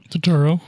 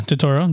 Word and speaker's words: Turturro: I'm saying it right, though, right? – Turturro. – Yeah, Turturro.